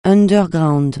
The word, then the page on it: underground: francais